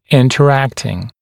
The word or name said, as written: interacting